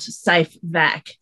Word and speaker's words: SAEFVIC: which is spelled S A E F V I C, and they're the adverse events following vaccination um, website, but it's actually the website itself is spelt safe vac, um, which is really confusing.